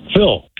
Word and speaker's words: Phil: Phil.